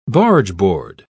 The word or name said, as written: bargeboard